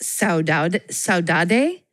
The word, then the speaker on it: saudade: And this, I believe it's pronounced saudade. S-A-U-D-A-D-E.